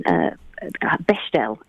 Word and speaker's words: Bechdel: I have chosen Fun Home, which is a wonderful story written by Alison Bechdel.